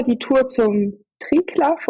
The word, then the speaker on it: Triglav: Also Triglav. – Okay. Ich werde manchmal unter YouTube-Videos nämlich berichtigt, dass es Triglau heiße. Okay. Also ausgesprochen. Aber ich nenne es auch Triglav.